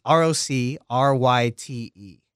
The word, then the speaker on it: Roc Ryte: It's R O C R Y T E.